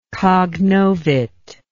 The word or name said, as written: cognovit